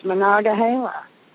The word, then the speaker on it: Monongahela: is from Monongahela. Did I say that right? – Oh, okay. I know exactly yeah. – where that is. Monongahela.